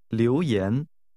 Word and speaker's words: 留言: 留言. – This is pronounced with two rising tones.